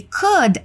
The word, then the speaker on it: could: Would é W-O-U-L-D e could é C-O-U-L-D. Na hora da pronúncia, o L se torna quase mudo e o O, U tem som de U.